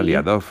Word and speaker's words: Liadov: Lo he dicho bien, ¿no? Era Liadov. – Sí, Liadov.